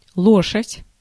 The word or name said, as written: лошадь